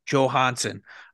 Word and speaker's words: Johansson: Johansson, did I say it right? – Johansson. Yes, it's not a Y. Pronounce the J.